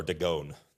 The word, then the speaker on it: Dagon: They brought it into the house of Dagon, the temple of Dagon, and set it by Dagon. Okay, so, or Dagon there, as some people pronounce it.